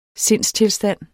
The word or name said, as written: SINDSTILSTANDE